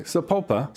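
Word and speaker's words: Sapulpa: This week we are broadcasting the Americana Road Show from the Shady Grove RV Park in Sepulchre. You pronounce it. – Sepulpa, Oklahoma.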